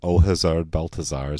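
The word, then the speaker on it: Hasard Balthazar: Hazard Balthazar, yeah. And please set me straight on how to pronounce that. – That'll do, yeah. That's fine. Hazard Balthazar is how I would say it.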